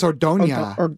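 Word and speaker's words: Ordaña: Ordana, but it's pronounced Ordonia, but it's Ordana. – Is there like a tilde over the N? – Yeah.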